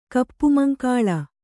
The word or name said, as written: ಕಪ್ಪುಮಂಕಾಳ